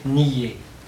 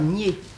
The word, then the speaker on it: Nied: Et puis, il y a aussi deux rivières de Lorraine qui doivent se prononcer nier. – Non, je ne crois pas. – Qui s'écrivent N-I-E-D, mais qui ne sont en pays de langue française. Et les gens du pays disent nier. Mais, alors, ils vous disent même, vous prononcez ça comme pied.